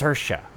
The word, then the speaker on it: Saoirse: It's Saoirse, which is so much easier than what I was saying. So that was the first team up between Saoirse Ronan and Greta Gerwig, and it was a match made in heaven.